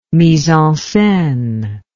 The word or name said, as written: mise en scene